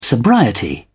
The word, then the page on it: sobriety: British English